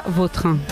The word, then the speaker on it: Vautrin: La original francesa, Vautrin.